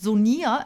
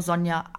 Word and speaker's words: Sonia: Unbedingt mit IA. Also ich heiße nicht Sonia, es wird ganz normal ausgesprochen, weil die meisten denken, weil ich mit I geschrieben werde, dass man das irgendwie anders aussprechen muss. Nein, einfach nur Sonja, aber halt mit I.